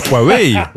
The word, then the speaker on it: Huawei: Comment on prononce? – Huawei. – H-U-A-W-E-I. – Huawei. – Ah ben voyez. – Huawei. – Qui c'est qui avait raison? C'est bien Huawei. Et ben voilà, si vous avez un smartphone Huawei, vous pourrez prendre votre température par infrarouge.